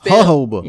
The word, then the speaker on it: jojoba: Yeah, it's a solid J. – Jehoba? – Not just, Jesus Christ. Jehoba. – Jehoba. – Yes. – It's okay. Spelled J-O-J-O-B.